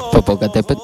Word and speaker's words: Popocatépetl: El final se pronuncia de una forma más acentuada. A ver cómo sería. – Popocatépetl.